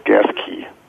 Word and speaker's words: Gasquet: There's a town between Grants Pass and Crescent City and the Redwoods called Gasquet, and it's pronounced Gasquet. – Okay. – The key to the gas tank.